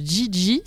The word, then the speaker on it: Geagea: Et c'est une artiste qui s'appelle Gigi, ça s'écrit G-E-A-G-E-A.